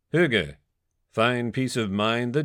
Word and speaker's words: hygge: First of all, let's start with how you say it. Pronounced Hygge,